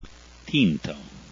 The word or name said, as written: tinto